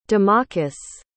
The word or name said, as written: domokos